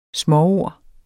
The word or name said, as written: småord